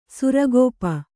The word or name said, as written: ಸುರಗೋಪ